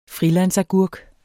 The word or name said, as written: friland